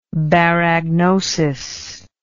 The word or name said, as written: baragnosis